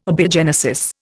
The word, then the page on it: abiogenesis: Indian